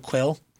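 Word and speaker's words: Kuill: So Quill.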